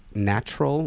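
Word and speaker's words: Gnatrol: There's a great product called Natural with a G, with a silent G.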